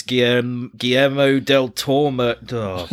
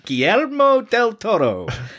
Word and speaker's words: Guillermo del Toro: Similar to Kevin and Perry go large, we've got Pan's Labyrinth, which is Guillermo del Toro. – Beautifully pronounced. – Thank you. Guillermo del Toro.